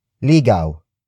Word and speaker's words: ligaw: This lesson is brought to you by the Tagalog word ligaw. Ligaw. Ligaw. Ligaw is spelled as L-I-G-A-W.